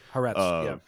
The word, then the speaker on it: Haaretz: Yeah, you know, something's really interesting to me that I've been seeing all week is that Haaretz, I don't know how to fucking pronounce this, sorry. – Haaretz, yeah.